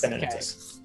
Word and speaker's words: Venenatus: I have to say it right, because it's like Venenatus. Yeah, it's Venenatus.